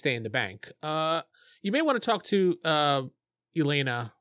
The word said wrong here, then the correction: Elna: or Elna. I keep saying her name wrong. Elna is her name.